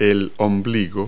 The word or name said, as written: navel